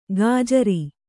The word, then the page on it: ಗಜ್ಜರಿ: gajjari